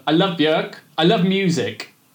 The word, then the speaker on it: Björk: Well, everyone who knows how it's properly pronounced calls it Björk, which is Icelandic for birch tree.